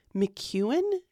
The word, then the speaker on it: McKeown: McKeown. Did I say his name right? – That's how I've been saying it.